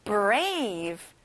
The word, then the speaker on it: brave: 용감한, brave. 친구들, 끝에 V 발음을 정확하게 해주세요. Brave.